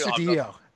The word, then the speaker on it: Astudillo: And now we're going to focus on another great athlete, the Williams Astudillo. Is that how you pronounce it, boys? Because I've got to be honest with you. – Astudillo.